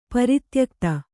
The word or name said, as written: ಪ್ರತ್ಯುಕ್ತಿ